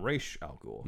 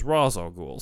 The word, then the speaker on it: Ra's al Ghul: So beforehand, the only adaptation of Ra's al Ghul was in the anime series where they called him Ra's al Ghul. – Mm-hmm. Yeah. – And Dennis O'Neill claimed that that was because somebody who either worked on the show or somebody he knew had studied Arabic and said that was the correct pronunciation. Then someone else came along who also claimed to speak Arabic and said the correct pronunciation was Ra's al Ghul.